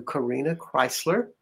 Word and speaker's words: Corina Chrysler: And I have a question about Pluto, but first I have to say you are listening to Corina Chrysler,